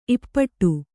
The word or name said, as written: ಈಪಾಟಿ